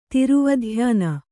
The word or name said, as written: ತಿರುವಧ್ಯಾನ